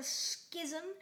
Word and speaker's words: schism: The Fire Starting Side and the Fire Fighting Side, which originally was one side until there was a schism. I believe that's how you say it. – Yes, it is.